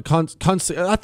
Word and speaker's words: concierge: Concierge? Concierge. Is that right? – Concierge. It's kind of a silent G.